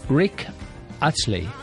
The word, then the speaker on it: Rick Astley: Rick Astley. What about my pronunciation? – It was very good.